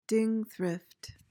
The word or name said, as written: dingthrift